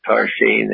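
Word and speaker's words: Tarxien: Now, the funny thing about the written Maltese is that they use a lot of X's instead of S's. So there's a lot of X's when you see Maltese, but they're pronounced like S's. So it's Tarshin is spelled with T-A-X-X-A-R. E-N-E, Tarshin.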